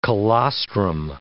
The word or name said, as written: colostrum